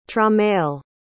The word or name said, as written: tramell